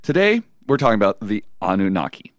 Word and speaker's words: Anunnaki: And if you're wondering how it's pronounced, it is Anunnaki.